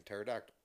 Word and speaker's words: pterodactyl: The P is silent, like in pterodactyl.